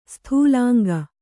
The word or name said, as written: ಸ್ಥೂಲಾಂಗ